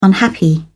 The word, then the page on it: unhappy: British English